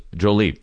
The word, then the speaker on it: Jolie: Pronounced Jolie. Jolie? Jolie.